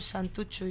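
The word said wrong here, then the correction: Santurtzi: ya no sé cómo se dice. – Santurchi.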